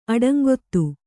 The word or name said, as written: ಅಡಂಗೊತ್ತು